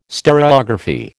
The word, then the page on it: stereography: American English